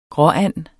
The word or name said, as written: gråand